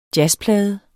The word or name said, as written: jazzplade